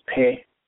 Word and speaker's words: Pe: The last letter is P, where it's pronounced in Hebrew as Pe.